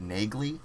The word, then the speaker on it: Neagley: So Neely, which is I always um, the the audiobooks and the spelling of it is always pronounced it Neagley. – Yeah, because that's it's, a G. Yeah. – Well, it's it's also just um, it's spelled N E A G L E Y, and I, I could see both pronunciations, so I, it doesn't it didn't bother me when they brought it up.